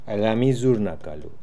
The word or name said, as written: Aliamizoornakalu